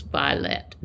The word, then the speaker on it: violet: And so I looked up the pronunciation, and it showed in most of the dictionaries online, anyway, two syllables, violet.